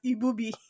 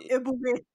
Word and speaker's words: Ebube: I've heard Ebube is pronounced as Ibubi. That one to be like Ibubi. It's Ebube. Oh my God! How did Ibubi come from Ebube? – It's spelled E B U B E, right?